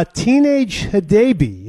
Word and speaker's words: Teenage Hadebe: How do you feel about our new center back, Tinaj, from Zimbabwe? Is that how you say his name? – Yeah, Teenage Hadebe.